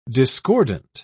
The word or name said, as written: discordant